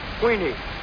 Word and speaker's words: Queenie: Queenie, not Heine. Queenie rhymes with meanie.